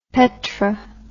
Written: petr

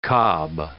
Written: cob

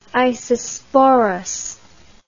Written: isosporous

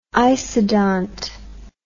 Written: isodont

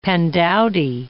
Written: pandowdy

